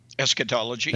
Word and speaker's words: eschatology: Eschatology, yeah.